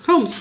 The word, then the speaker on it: Holmes: Oder Holmes. – Holmes, genau.